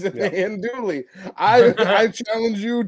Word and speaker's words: Andouille: Andouille sausage. Isn't that what it's called? – I think it's Andouille. You don't pronounce the L's.